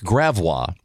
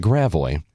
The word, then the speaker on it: Gravois: And nobody told them the local pronunciations, like Gravois, instead of Gravois. – Yeah, that's funny. – That is such Gravois.